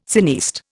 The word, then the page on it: cineaste: Indian